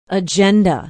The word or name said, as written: agenda